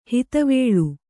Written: ಹದವಳಿ

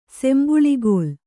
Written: ಸಂಬಳಿಗೋಲು

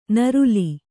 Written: ನರಲೆ

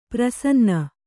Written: ಪ್ರಸನ್ನ

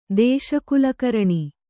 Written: ದೇಶಕುಲಕರಣಿ

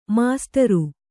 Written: ಮಾಸ್ಟರು

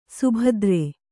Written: ಸುಬೇದಾರ

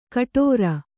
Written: ಘುಟುರು

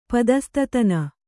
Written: ಪದಸ್ತತನ

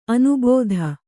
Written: ಅನುಭೂತ

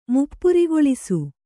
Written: ಮುಪ್ಪುರಿಗೊಳಿಸು

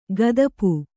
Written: ಗದಪು

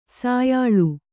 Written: ಸಾಯಾಳು

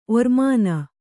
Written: ಒರ್ಮಾನ

